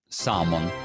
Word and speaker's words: salmon: Allora, questa la pronuncierei salmon, yeah. Senza la L, exactly.